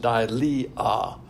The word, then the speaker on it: deilia: And the word timidity is dilea, and that's spelled D-E-I, L-I-A, but pronounced di-li-a, and that means fearfulness.